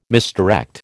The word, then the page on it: misdirect: American English